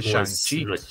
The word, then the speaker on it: Shang-Chi: Shang-Chi. – por ejemplo, el chi no es ah. como nuestra che, es como una, lleva como una T, es como un TZ, chi.